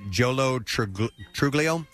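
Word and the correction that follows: Joe Lo Truglio: I would say Joe Lotruglio.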